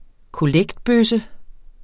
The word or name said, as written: kollektbøsse